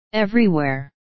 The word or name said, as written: everywhere